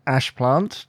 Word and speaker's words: Ashplant: And your name is Dan Ashplant, just to know. So that's A-S-H-P-L-A-N-T. Have I pronounced that correctly? – You have pronounced that correctly.